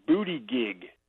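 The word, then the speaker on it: Buttigieg: You guys keep mispronouncing the name of the Secretary of Transportation. It's Booty Gig.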